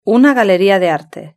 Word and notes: una galeria de arte: European Spanish

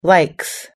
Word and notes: likes: American English

